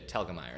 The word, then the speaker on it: Telgemeier: Telgemeier.